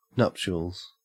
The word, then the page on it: nuptials: Australian English